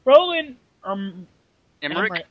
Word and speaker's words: Roland Emmerich: Written by Ronald Mitrin. Roland. – Come on, dude, they're Americans. You should be able to pronounce their names. – I've never heard of their names. Roland Emmerich? – Emmerich, thank you. Roland Emmerich, Dean Devlin.